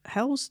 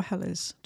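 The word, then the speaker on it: Helles: And again, this is a Hell's, did you say, or Hellers? Do you call it Hell's? – You can pronounce it either one.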